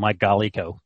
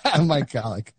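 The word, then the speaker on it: Mike Golic: Also, fun fact, Mike Golick was going to do the show until Brinson called him Mike Golico. – Mike Golick.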